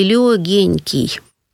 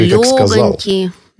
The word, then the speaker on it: легонький: А на самом деле слово легонький, да, мягонький, эти прилагательные нужно произносить именно так, потому что в этих прилагательных пишется суффикс «оньк».